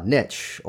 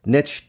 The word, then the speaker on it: niche: niche or a niche or a specialty. And yes, niche does rhyme with rich.